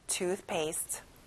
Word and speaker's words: toothpaste: 친구들, 가운데 f 발음 다시 한번 잘해보고요. One more time. toothpaste.